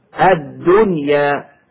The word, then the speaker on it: الدنيا: كما ننطق بالنون الساكنة مظهرة قبل الياء هكذا: وما هذه الحياة الدنيا إلا لهو ولعب، الدنيا.